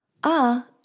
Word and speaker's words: 啊: and is pronounced longer than usual, like this. Ah. Ah and 我说呢 are often used together.